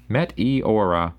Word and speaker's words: Meteora: Also got Linkin Park's Metaura? I don't know what that one is. How do you pronounce that? Meteora. Meteora. Metaura. Sure.